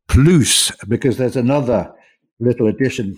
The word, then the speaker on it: plus: you say plus, pronounced that way, plus, P L U S.